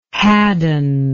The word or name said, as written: haddon